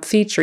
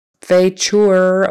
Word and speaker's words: feature: It's pronounced feature, even though it's spelled F-E-A-T-U-R-E. It's not feature or whatever, it's feature.